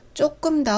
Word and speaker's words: 走: 조, Z, O, U로 병음 표기를 합니다. 워랑 똑같이 삼성이고요. 조, 조. 제가 틈틈이 병음 표기를 알려드리고 있죠. 그 이유는 조만간 간단히 설명해 드리겠습니다. 다시 발음해 볼게요. 조, 오와 어의 중간 크기로 입을 벌려서 저 발음을 해주시면 좋습니다. 간다 라는 의미예요.